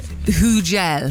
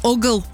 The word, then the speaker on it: Hugel: How do we say it? – Hugel. – Hugel. – Hugel. – H-U-G-E-L. How do you say it if you're from Yorkshire? – Uggle. – Uggle.